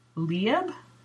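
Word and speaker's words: Liab: And I knit Lieb, I think that's how you say it. It's L-I-A-B.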